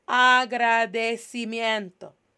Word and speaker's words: agradecimiento: Agradecimiento, el agradecimiento.